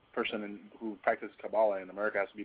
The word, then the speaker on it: Kabbalah: Kabbalah, it's pronounced Kabbalah in Hebrew.